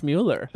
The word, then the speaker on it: Mueller: You said your I don't think so, honey, on Robert Mueller was it should be pronounced Mueller.